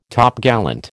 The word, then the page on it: Topgallant: American English